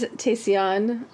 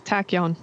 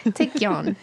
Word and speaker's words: Taecyeon: Is Taesyeon or Taesyeon? How would you say that? – I think it's Taecyeon. – Yeah, Taecyeon. I'm like, right, Taesyeon, who are we talking about? – We all know that my pronunciation is horrid. – Taecyeon. – Yes, Taecyeon. I like him.